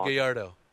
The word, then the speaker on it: Gallardo: I'm pronouncing his name. – Yeah, Giovanni Gallardo. – Yeah, Gallardo, thank you.